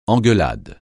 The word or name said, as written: engueulade